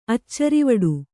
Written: ಅಚ್ಚರಿವಡೆ

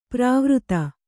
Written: ಪ್ರಾವೃತ

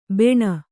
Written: ಬಾಣ

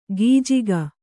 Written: ಗಿಜಿಗ

